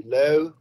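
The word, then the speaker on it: löyly: Am I saying that right first? – Well, yeah, it's actually lolu. Lolu.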